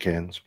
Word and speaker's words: Cairns: C-A-N-N-E-S? No, but apparently you don't pronounce the R. – It's been a few years in Australia. We used to call it Cairns.